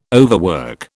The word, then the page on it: overwork: British English